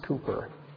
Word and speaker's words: Cowper: Looks like Cowper, actually pronounced Cooper.